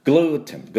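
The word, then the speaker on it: gluten: タンパク質の一種なんですけども、これもよく出てますので、グルテン、グルテンの発音はですね、グルグルダブルシーン、ダブルシーンでグルーでちょっと伸ばしてグルーテン、グルーテン、グルグルグルグルーテン、こんな音なんです。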